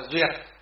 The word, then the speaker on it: duyệt: Chữ thuyết này còn có một âm nữa đọc là duyệt.